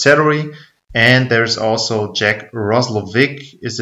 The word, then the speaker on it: Roslovic: I think everyone here has pronounced it Roslovic to this point, so kind of a hard C.